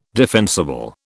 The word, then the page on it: defensible: British English